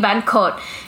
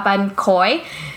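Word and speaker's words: bánh khoái: Because people hear me and they're like, oh, you mean ban kot. And I'm like, no, not ban kot from Vung Tau. I'm talking about ban koi, which is like a ban sale, but it's thicker and it's crunchier.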